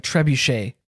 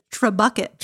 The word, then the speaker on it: trebuchet: You used the proper French pronunciation. – Well. – Pronunciation. – Okay. Don't give me too much credit. – Instead of trebucket.